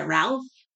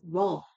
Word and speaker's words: Rolf: They were like, is it Ralph? Is it Rolf? No, I'm like, it's Rolf.